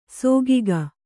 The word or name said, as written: ಸಿಗ್ಗು